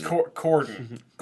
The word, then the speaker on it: Corden: I think it's Corbin. – People are going to have a hard time differentiating between Colbert. – James Corden. Corden. So Corden.